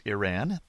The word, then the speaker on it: Iran: He says it's pronounced I as in Indiana, I, and Ran as in run, so it's Iran.